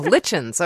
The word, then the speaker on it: Litchens: Well, I told myself it rhymes with kitchens, so Litchens, okay.